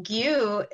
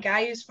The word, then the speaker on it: Gyu: And gyu is actually like the real pronunciation. I mean, say it however you want, but I mean, Gayu's fine, but Gyu is like the actual how you would pronounce that name.